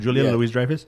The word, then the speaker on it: Julia Louis-Dreyfus: Julia Louis-Dreyfus? – Yes.